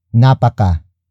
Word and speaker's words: napaka: The second way to turn Tagalog root words into superlative adjectives is by adding the prefix napaka. Napaka. Napaka. Napaka is spelled as N-A-P-A-K-A.